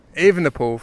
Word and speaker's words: Evenepoel: He's currently just under 2 hours down on Remco Evenepoel. I got told off my pronunciation by a Belgian this morning. Evenepoel from now on.